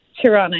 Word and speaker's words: Tirana: And the story that kicks off your book is about the capital city of Albania, which is a place called Tirana. Am I pronouncing that correctly? – Yes, Tirana, yes.